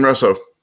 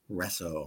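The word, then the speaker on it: Reso: Now, how do you say that? Is it Riso or Riso? – Jason Riso. – Riso, all right.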